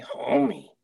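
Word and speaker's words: Homme: So now Josh Homie, I've been saying his name wrong all this time. Is X, you gotta pronounce the E at the end. – You gotta say Homie.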